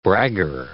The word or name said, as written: bragger